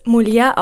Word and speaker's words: Mulier: Man spricht es nicht Französisch aus, aber ich habe jetzt schon alles fast gehört, wie man es ausspricht. Aber offiziell spricht man es MULIER aus, das einfach nur auf Latein Frau bedeutet.